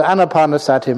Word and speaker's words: anapanasati: Anapanasati, mindfulness along with the breath. It's not pronounced anapanasati, which is mindfulness along with pain.